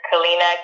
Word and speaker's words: Kalyna: So my name is Kalyna, K-A-L-Y-N-A, and Miletic, as you said so beautifully at the beginning.